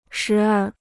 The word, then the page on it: 时而: shí ér